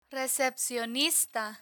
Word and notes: recepcionista: Spanish

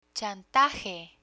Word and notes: chantaje: spanish

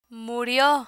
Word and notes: murio: Spanish

